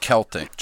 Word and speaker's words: Celtic: And actually, it's pronounced Celtic, just so you know. – You want to correct every word C-E-L-T-I-C. It's Celtic.